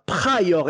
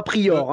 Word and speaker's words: Pryor: et encore, j'ai fait l'effort de dire Pryor et non pas Prior, hein, quand même, attention.